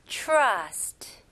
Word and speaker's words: trust: Trust, trust, trust. 오케이? 여기에서 이제 TR로 시작하는데 느낌은 살짝 CH 발음으로 한번 해보세요. Trust, 오케이? Trust보다는 CH 발음. Trust.